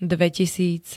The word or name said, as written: dvetisíc